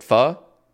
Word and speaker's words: pho: Why do you like pho? So how do you pronounce it? Pho? – Yeah, pho. – Is it spelled P-H-O? – P-H-O. – Oh, interesting. Pho.